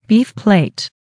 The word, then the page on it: beef plate: American English